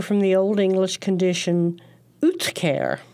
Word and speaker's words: uhtceare: It's spelled weirdly, too. U-H-T, not U-T-H, but U-H-T-C-E-A-R-E, Utcare.